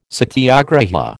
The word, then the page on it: satyagraha: American English